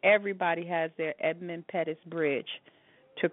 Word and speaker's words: Edmund Pettus: I think I'm saying the name right, Edmund Pettus.